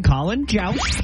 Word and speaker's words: Colin Jost: Colin, I think I'm going to say it right, Colin Jost. – We're going to lock in Colin Jost.